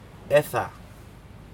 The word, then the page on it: eda: Aneityum